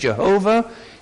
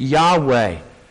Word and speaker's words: Yahweh: In Hebrew, it's four letters, YHVH, or in English, JHVH. You can pronounce it Jehovah. You can pronounce it Yahweh. Something like that. The Hebrews did not have vowels in their alphabet, so we're kind of putting the vowels there.